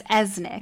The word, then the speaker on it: Oeznik: or valet or whatever his name is as his version Esnick. of Alfred. – Yes, it's Esnick, yeah. but it's spelled O E Z N I K.